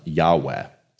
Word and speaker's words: Yahweh: which there's a lot of debate about how you should pronounce it, but kind of the most scholarly consensus, which you've seen no doubt in the past year in our worship guides and our worship service, is Yahweh or Yahweh.